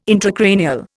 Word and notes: intracranial: Indian

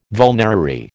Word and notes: vulnerary: American English

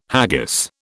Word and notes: haggis: British English

